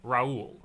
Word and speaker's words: Raoul: So Paul is a one-syllable name. – It is. – You add a line to the P to get an R, and then you get a two-syllable name, Raoul.